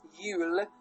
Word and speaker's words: Yule: Yule, J-U-L-E, is the way to pronounce it. Very hard Y, a Yule.